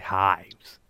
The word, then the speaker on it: Hives: Dije de Hives, es de Hives. Cuesta pronunciarlo.